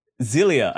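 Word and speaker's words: Xillia: Zilia, I think, is how you say that middle one. Tales of Zilia. With an X.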